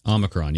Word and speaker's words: Omicron: But see, they're using the selling point because Omicron, is that how you say it? Omicron? – Omicron, yes.